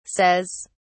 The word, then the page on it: says: British English